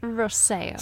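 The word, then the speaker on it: Rosselló: gotta correct me on my pronunciation, man. – Rosello. – Rosello. Okay. – God, I hate you both. – Because you can't roll your R's.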